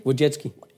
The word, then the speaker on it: Wojcicki: Wojcicki. – Esther Wojcicki, fascinating person.